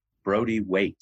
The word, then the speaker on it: Brody-Waite: I thank you very much for joining us, Michael Brody Waite.